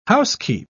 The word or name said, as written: housekeep